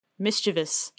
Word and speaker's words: mischievous: Mischievous. – Mischievous? – There's not even an eus in there. That's not how it's spelled, mischievous.